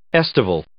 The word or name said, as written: estival